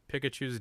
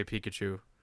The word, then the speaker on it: Pikachu: My wife makes fun of the way I say Pikachu. Pikachu. – You sound like you're saying peek at you. – Yeah, see, I say it wrong for sure.